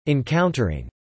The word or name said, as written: encountering